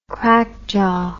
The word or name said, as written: crackjaw